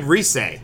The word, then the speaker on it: Rise: Hey, I pronounce them the way they're spelled. Rise is spelled R-I-S-E. Rise. – What's it actually? Reese? – Yeah, it's Reese.